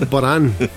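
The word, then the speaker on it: Bodhrán: It's spelled Bodhran. – Well, I fucked up then, yeah. – I'm sure it's called Boran. – It's called Boran.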